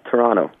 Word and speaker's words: Toronto: So up there, how do you say it up there? – Toronto. – Toronto. They don't pronounce the T.